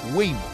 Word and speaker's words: Waymo: Se llama ¿Cómo? Waymo. Waymo.